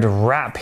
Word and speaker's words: wrap: The article used the word wrap here, which has a silent W, W R A P, and this word is also a verb.